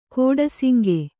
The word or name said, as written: ಕೋಡಸಿಂಗೆ